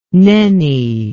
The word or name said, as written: nenni